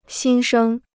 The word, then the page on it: 新生: xīn shēng